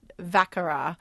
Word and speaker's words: Vacqueyras: But Gigonda, there's one place next door, if you can find it, to Gigonda and Châteauneuf de Pape called Vacara, and that's even a better deal because it's a little newer in terms of accreditation at this level. It's spelled VACQUEYRAS. Yes, whoa. – That's why I had to spell it.